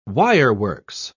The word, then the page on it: wireworks: British English